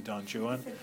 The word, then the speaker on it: Don Juan: As Byron parodies it in his great, great, great, great, a thousand times great work, Don Juan, pronounced correctly, Don Juan.